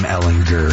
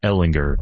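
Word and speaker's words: Ehlinger: It is Sam Ellinger. Hard G. Not a Sam Ellinger.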